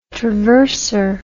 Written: traverser

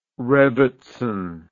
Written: rebbetzin

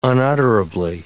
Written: unutterably

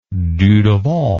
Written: Dudevant

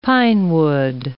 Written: pinewood

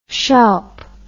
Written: shoup

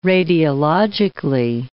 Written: radiologically

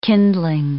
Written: kindling

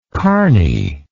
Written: carny

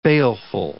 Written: baleful

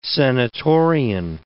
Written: senatorian

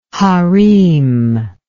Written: hareem

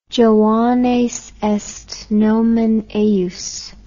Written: Joannes est nomen eius

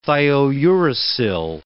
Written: thiouracil

